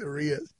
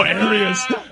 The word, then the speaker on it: Arias: Alex Arias. – Oh, Arias. I loved Alex Arias. – I just, why did I change the pronunciation on his name? – I don't know. – Arias to Arias.